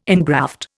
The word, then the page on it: ingraft: Indian